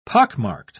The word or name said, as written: pockmarked